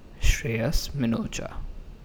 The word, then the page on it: Shreyas Minocha: shr-AY-us min-o-cha; /ʃrˈeɪəs ˌmɪnoˈtʃä/; श्रेयस मिनोचा